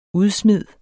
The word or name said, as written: udsmid